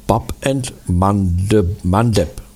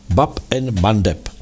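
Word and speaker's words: Bab al-Mandab: Oni, že jo, zastavili dopravu i v té bráně nářků, a vyslovuje se to Bab and Mandeb. Bab and Mandeb.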